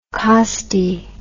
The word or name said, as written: costae